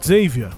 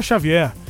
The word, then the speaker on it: Xavier: Xavier Becerra que é Xavier aqui no bom português, né? Lá no inglês eles falam Xavier, porque o X lá se pronuncia de um jeito diferente. Pra gente é Xavier Becerra.